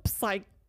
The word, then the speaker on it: psyched: E o Pedro ficou tão psyched, o pé não se pronuncia.